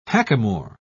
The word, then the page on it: hackamore: British English